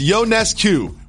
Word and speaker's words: Ionescu: First off, I want to say that is how you say her last name. It's Ionescu. We've been saying it wrong for quite some time.